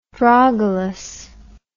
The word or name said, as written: drogulus